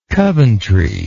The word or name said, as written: coventry